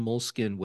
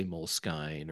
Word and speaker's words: Moleskine: And if you go to the Moleskine website, and people say Moleskine or Moleskine, or I've heard a bunch of different pronunciations.